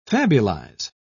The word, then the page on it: fabulize: British English